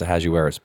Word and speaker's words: Ahasuerus: I mean, now that I see the name, I'm like, oh yeah, I could totally see how it's pronounced Ahasuerus.